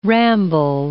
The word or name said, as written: ramble